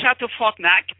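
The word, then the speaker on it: Château Frontenac: Fairmont Le Chateau Frontenac.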